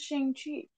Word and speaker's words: Shang-Chi: It's Shang-Chi because it's like chi, like the energy inside of his body.